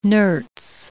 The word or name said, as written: nerts